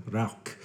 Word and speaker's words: Rauch: I'm like, oh, it's not pronounced Rausch. He's like, Rauch. Rauch.